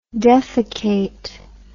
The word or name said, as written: defaecate